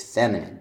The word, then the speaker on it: feminine: It's feminine, da-da-da. The third syllable doesn't have a stress, so that's not perfect prosody.